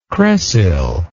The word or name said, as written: cresyl